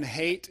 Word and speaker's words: Haidt: Um, Jonathan Haidt is a researcher. H A I D T is how his name is spelled. So that would be ironic, right, if his name is hate.